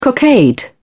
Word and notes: cockade: British English

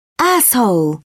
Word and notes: asshole: British English

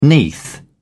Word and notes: neath: British English

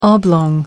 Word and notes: oblong: American English

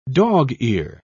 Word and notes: dog-ear: British English

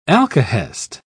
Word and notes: alkahest: British English

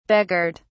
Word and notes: begirt: British English

